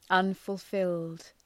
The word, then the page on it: unfulfilled: ,ʌnfʋl’fıld